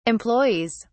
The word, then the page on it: employees: British English